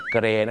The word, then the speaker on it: Gray: Gray, G-R-E-Y, Gray เนี่ยนะฮะชาวอเมริกาจะเป็น G-R-A-Y ก็เป็น Gray นั่นเองครับอย่าลืมนะครับคำนี้ต้องออกเสียงรอเรือเพราะบางคนเนี่ยนะฮะเห็นฝรั่งใส่เสื้อสีเทามาไปบอกฝรั่งว่าโอ้ I like your gay shirt ชอบเสื้อเกย์ของเขา Gray